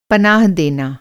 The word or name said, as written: पनाह